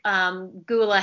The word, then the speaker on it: Gyula: So, for example, the sport leader who was really big in the 1950s and 1960s, his name is Gyula Hegyi. In English, it would kind of sound like Gula Hegyi.